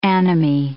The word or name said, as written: anomie